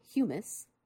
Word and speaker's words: humus: they become something called humus, which is spelled almost like hummus. And I always thought it was hummus when I was reading it in textbooks, but it's not. It's humus, H-U-M-U-S.